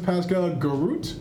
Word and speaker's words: Pascal Garoot: No, check my man on Spotify, check my man on SoundCloud, iTunes, Pascal Garoot, right? – Correct. – I want to make sure I'm not mispronouncing that.